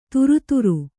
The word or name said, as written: ಥರಥರ